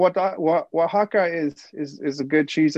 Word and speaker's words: Oaxaca: It's O A X A C A cheese. Quesillo. – Oaxaca. – Oaxaca. Quesillo. Yeah.